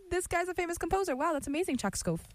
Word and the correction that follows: Tchaikovsky: Whoa. No, I gotta pull up the pronunciation. Hold on. No one say anything. Tchaikovsky and Solitude.